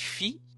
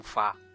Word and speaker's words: Fi: Muita gente chama de Fi, mas inclusive eu. – A pronúncia oficial, né, já entrando na explicação do nome, é justamente da... Ele vem da nota musical mesmo, Fá.